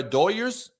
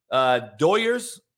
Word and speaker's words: Doyers: If you're a Doyers, we say Doyers here in LA from the incredible Hispanic fan base. Doyers are how the Los Angeles Doyers are pronounced.